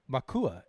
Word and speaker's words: Macuá: It looks like it's El Macuá, M-A-C-U-A, with an accent on the A, so Macuá.